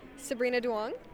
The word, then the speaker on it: Sabrina Duong: Sabrina Duong. – And could you spell it for me? – S-A-B-R-I-N-A space D-U-O-N-G.